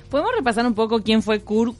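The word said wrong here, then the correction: Cukurs: En realidad se pronuncia, en letón se pronuncia Sukurs.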